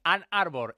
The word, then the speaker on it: Ann Arbor: Vamos a hablar del Real Madrid, que está, a ver si lo digo bien, si no ahora que me corrija el compañero Edu Pidal, en Ann Arbor, en Michigan. Edu Pidal, buenas noches. – Muy buenas, pronunciación perfecta.